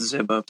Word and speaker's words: Ziba: Am I saying that right? Ziba? – I pronounce it Ziba.